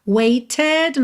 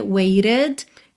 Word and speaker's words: waited: Então, nesses dois, waited, nós temos rarara no T, waited. Claro que se você quiser, pode cantar how long I have waited. Dá até um pouco mais de ênfase para essa espera, né? Mas, Diana Krall, por exemplo, faz rarara nesses T's, tá?